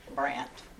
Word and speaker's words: Brant: Billy, B-I-L-L-Y, Brandt, B-R-A-N-T.